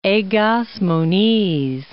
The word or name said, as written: Egas Moniz